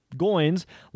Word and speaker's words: Goins: But the way he explains it is it's Goins, like the word coins, but with a G.